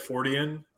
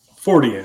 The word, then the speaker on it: Fortean: I've always said Fortean, and the reason is because, you know, it's based on the name of Charles Fort. And so I just thought, you know, if you turn that into an adjective or something, then you would say his name and then just add the suffix to it.